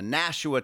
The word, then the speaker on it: Nashua: This is from the Nashua Telegraph, Nashua, New Hampshire. – So proud of you for pronouncing that May correctly.